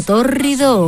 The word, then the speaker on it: tórrido: Un verano tórrido o un tórrido romance. Este adjetivo es proparoxítono, menudo palabro, no es ninguna enfermedad. Significa que es esdrújula, que lleva el acento en la antepenúltima sílaba.